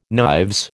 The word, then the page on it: knives: American English